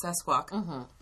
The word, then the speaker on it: Sasquatch: And they're saying also by denying that Sasquatch or Bigfoot or Sesquatch, as the Native Americans say, exists, you're also putting us at risk.